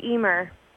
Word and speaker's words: Emer: It's a Gaelic name. It's an Irish name, Emer.